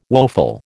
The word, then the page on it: woeful: American English